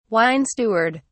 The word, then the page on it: wine steward: British English